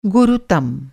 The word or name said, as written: गुरुतम